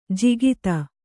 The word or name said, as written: ಜಕ್ಕಾತಿ